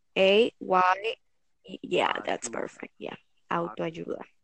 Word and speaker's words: autoayuda: I don't remember the word in English, but in Spanish it's autoayuda.